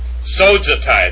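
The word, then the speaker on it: sozetai (σώζεται): The next one is sozetai, that's how you would pronounce this, sozetai, and see, it's got a zeta in it, and we said before that a zeta is pronounced with a D, like a DZ.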